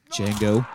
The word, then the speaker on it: Django: Django. Can you spell it? D-J-A-N-G-O. The D is silent.